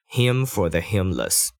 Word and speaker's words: hymn: This is a hymn for how we live. Hymn 就是圣诗哦，H Y M N。嗯，但它的发音跟就是第三人称男生的那个他的受格 H I M him 是同一个发音，但就是要注意一下前后文，不要搞错这两个字了。